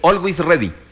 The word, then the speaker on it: Always Ready: ¿De quién? Alguaiz. – ¿De ¿Sí? quién? ¿Se dice así? – Always Ready. – ¿Cómo? – Always Ready. – Always Ready. Sí.